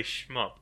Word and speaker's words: shmup: As far as shumps go, this isn't the best example of it. – Is that how you pronounce it? – Hmm. – I would normally say shmup.